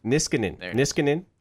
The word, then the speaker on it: Niskanen: Matt Niskanen, is that right? – Niskanen.